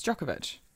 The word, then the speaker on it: Djokovic: I love Novak Djokovic. Have I said that right? Novak Djokovic. – Is it Djokovic? It's Djokovic.